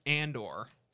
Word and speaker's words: Andor: The first one is Andor.